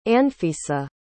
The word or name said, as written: anfisa